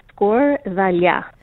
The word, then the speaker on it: skordalia: It's pronounced skordalia. – Skordalia. Okay. – Tough, but you got it.